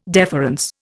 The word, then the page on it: deference: Indian